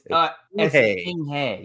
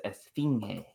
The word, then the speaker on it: Esfinge: It's Esfinge. Yeah. – Esfinge. – I was saying it, but I forgot the G is silent in Spanish, so it's Esfinge.